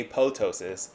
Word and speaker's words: apoptosis: But I just learned recently, everyone pronounces apoptosis. Apparently that second P is silent. Did you know that? – I did not know that. That's why I pronounced it apoptosis. – So it's actually apoptosis, and the second P is actually silent.